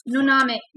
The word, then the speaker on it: Noname: Noname. – Noname? No name, I like it. – Nice. – Accent on the... – On the A.